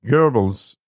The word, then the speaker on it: Goebbels: But see, I'm pronouncing it Goebbels because there's no R in there.